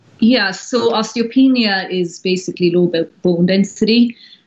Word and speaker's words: osteopenia: Osteopenia. Is that the correct? Am I pronouncing that correctly? – Yeah, osteopenia.